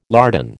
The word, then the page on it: lardon: American English